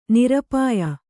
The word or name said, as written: ನಿರಪಾಯ